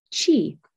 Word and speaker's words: qi: And these energetic highways allow for the flow of energy, known as qi, or it's in Chinese, they spell it QI.